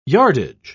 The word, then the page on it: yardage: English; British English